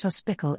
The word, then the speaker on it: hospital: The use of ickle, in South Yorkshire and particularly in the Rotherham area, hospital is pronounced hospickle and little is pronounced lickle.